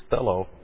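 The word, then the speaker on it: thelo (θέλω): Actually, the word here for willing, we've seen a couple, especially the most common word that we've seen here is thelo. T-H-E-L, long O for will.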